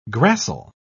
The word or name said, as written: gracile